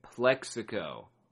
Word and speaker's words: Plaxico: Plexico. Plex. Plex. Even though it's spelled P L A X, it's pronounced like P L E X, like Plex pipe.